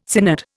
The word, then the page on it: synod: Indian